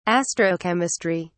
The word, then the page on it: astrochemistry: British English